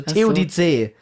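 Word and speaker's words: Theodizee: Und es wird wie TOD und dann ZEE, also TODC.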